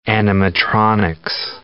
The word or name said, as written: animatronics